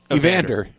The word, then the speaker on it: Evander: Oleander Holyfield before he went in the ring. – Hold on. Who Holyfield? – How do you say it? Oleander Holyfield? – Evander. Oleander is a flower.